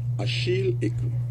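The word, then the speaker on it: Achille Ekeu: Achille Ekou. – Achille Ekou. So E-K-E-U.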